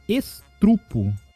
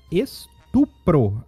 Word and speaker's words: estupro: Outra que eu erro bastante para corrigir é, é para falar, na verdade, né, para pronunciar, é o estrupo, que o correto é estupro na a pronúncia.